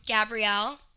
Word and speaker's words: Gabrielle: Gabrielle, and that's spelled like the girl's name, GABRIELLE, not Gabrielle, _ Loren, like Sophia Loren.